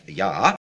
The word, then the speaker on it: Jah: Hallelujah, of course, is from the Hebrew word for praise with the name of God on the end, Hallel, and it looks in English like Jah, but I believe it's pronounced Yah, and it is a transliteration from the Hebrew meaning praise the Lord.